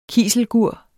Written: kiselgur

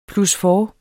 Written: plusfours

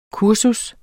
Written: kursus

